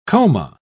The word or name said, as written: coma